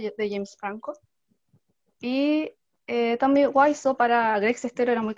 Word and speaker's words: Wiseau: y ya lo busqué, Wiseau, porque no sabía cómo se pronuncia, Tommy Wiseau, y cómo él ideó esta película.